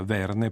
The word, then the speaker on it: Verne: ma naturalmente lo pronunciava Verne perché doveva far rima con lanterne.